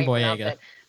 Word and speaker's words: Boyega: And is it John Boyega? Is that how you pronounce it? I think so. – Yeah, John Boyega.